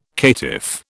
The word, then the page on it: caitiff: British English